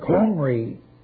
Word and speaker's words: Omri: In 722 B.C., Samaria, the capital city, made such by Omri, pronounced with a harsh aspirant, Omri, the sixth king, and thereafter called by the Assyrians, House of Omri, or Simri, or Kimri.